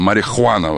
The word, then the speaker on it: marihuana: Марихуана вот так поет. Это неправильно. Это что-то, какой-то облом Петрович. По-испански марихуана. Там нет, H не читается.